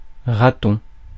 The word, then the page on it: raton: /ʁa.tɔ̃/; France